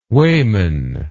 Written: weighman

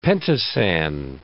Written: pentosan

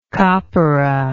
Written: copperah

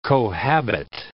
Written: cohabit

